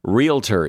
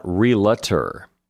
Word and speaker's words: realtor: Realtor is another one. It's not realtor. There are not three syllables in that word. It's just realtor.